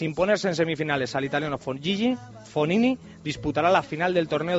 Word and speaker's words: Fognini: G antes de N, Fognini.